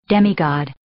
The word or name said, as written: demigod